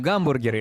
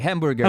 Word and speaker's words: гамбургер: А история, на самом деле, как бы сказать, совершенно странная, потому что амбургер придумали еврейские... – Слушай, мне так нравится, как ты говоришь «амбургер». Я просто привык к произношению «гамбургер» или «хэмбургер». – «Амбургер». – «Амбургер». На израильский манер говоришь «амбургер».